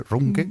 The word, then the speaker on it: Ruhnke: Y, nada, os dejamos ahora con una entrevista, que una pequeña entrevista que pudo hacer Yolanda a Volko Rulker, ¿cómo se dice? – Runke, mm.